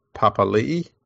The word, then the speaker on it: Papali'i: Josh Papali, they've now pronounced his surname, so it's exactly the same as the former Warriors and now Eels player Isaiah Papali'i.